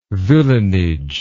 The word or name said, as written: villeinage